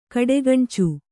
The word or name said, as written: ಕಡೆಗಣ್ಚು